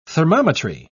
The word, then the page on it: thermometry: British English